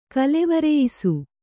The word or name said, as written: ಕಾಲ್ವರಿಯಿಸು